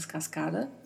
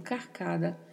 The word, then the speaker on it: cascada: Mais uma vez, a letra D não é muito marcada, assim como na palavra sonido, cascada também não é cascada. Falamos, falamos cascada. E o som da letra D é mais suavizado, né?